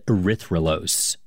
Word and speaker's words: erythrulose: And they scanned it for radio signals of different molecules, which is how they found a four-carbon sugar called erythrulose. – I've heard of, like, glucose and fructose, but, like, how do you say that? – Erythrulose.